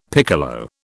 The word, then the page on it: piccolo: British English